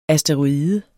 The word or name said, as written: asteroide